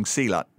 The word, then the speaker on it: Silat: Silat, yeah. Like the sport.